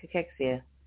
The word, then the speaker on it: cachexia: We're trying to figure out, is it cachexia or cachexia? Yeah. Oh, here. Here it is. How to pronounce. Okay. Even the guy. All right. Okay, let's do one more. Where was yours coming from? Oh. All right, cachexia. There it is, body wasting.